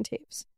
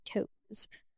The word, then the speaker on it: Toews: It's directed by Sarah Polley, written by Sarah Polley and Miriam Taves, and based on the novel by Miriam Taves. You would not guess that's how you pronounce her name, because it is spelled T-O-E-W-S, and I wish it was pronounced toes, but it's not.